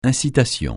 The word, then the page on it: incitation: francais